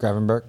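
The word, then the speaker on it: Gravenberch: How do you pronounce the midfielder? Gravenbach? – Gravenberg.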